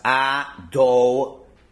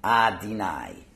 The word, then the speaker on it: Adonai: The next word is, it just has a hill over there, is pronounced Adonoi. Okay, and again, if you're a Sephardi, it would end with Nai, but you're not a Sephardi, so it's Adonoi. And you have to emphasize the do, because I've often heard people say the word pronounced Adonai.